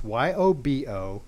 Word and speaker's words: Yobo: and now he's formed a company called it's YOBO. I believe it's a Korean word, I think, and it's actually pronounced Yobo.